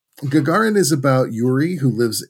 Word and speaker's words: Gagarin: It's good for ages 13 and up, and it's a film called Gagarin.